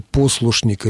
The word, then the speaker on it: послушник: как бы он поставил ударение в слове «послушник» или «послушничество». Сегодня почти невозможно услышать традиционное правильное ударение на первом слоге. В слове «послушник» всего 3 слога, но даже в 3-сложном слове ударение переходит на 2-й.